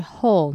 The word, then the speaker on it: whole: The whole idea is if you're too skinny, you won't be looked at as fertile is one thing. – 在练习这个句子的时候，在 whole 那边记得那个 L 就比较轻微的去发音就可以，然后那个 whole 是跟地下里面会有的 whole 其实发音是一样的。